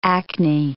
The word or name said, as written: acne